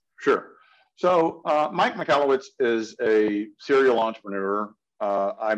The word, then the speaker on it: Michalowicz: Michael Witz, I don't know if I'm pronouncing that right. – Michalowicz. – Yes. Thank you. The wrong emphasis on the incorrect syllable, but what is the Profit First model?